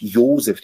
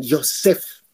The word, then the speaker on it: Josef: Nicht Josef, dieses langweilige Josef, das ist so billig, sondern Josef.